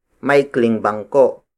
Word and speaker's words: maikling bangko: Maikli is spelled as M-A-I-K-L-I. It means short. For example, maikling bangko. Maikling bangko. Maikling bangko. Maikling bangko is spelled as M-A-I-K-L-I-N-G, space B-A-N-G-K-O.